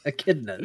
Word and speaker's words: echidna: Echidnas.